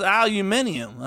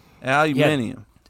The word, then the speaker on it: aluminium: Hey, and it's pronounced aluminium, all right? I ain't going to fucking have it no more, all right? Aluminium.